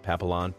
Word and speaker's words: Papillon: Lucy the Labrador, Sheriff the Golden Aussie Mix, and Martini, I can't pronounce it, P-A-P-I-L-L-O-N. Dog people, help me out. Papillon. Something like that? – Papillon.